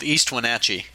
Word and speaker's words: East Wenatchee: Little town called East Wenatchee. And I asked, and that's the correct pronunciation.